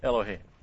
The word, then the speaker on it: Elohim: It's a short O here. There we go. Not the long O. Elohim.